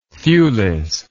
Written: thewless

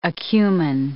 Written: acumen

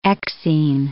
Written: exine

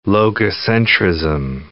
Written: logocentrism

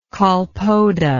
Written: colpoda

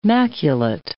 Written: maculate

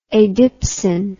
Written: adipsin

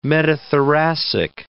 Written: metathoracic

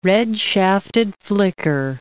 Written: red-shafted flicker

